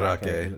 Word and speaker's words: Mandarake: I mean, it's spelled Mandrake, but it's pronounced Mandrake. – Mandarake. – It's, Because it's Japanese.